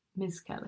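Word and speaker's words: Misskelley: I think I'm saying it right. It's M-I-S-S-K-E-L-L-E-Y. Miss Kelly. – Oh, is one word.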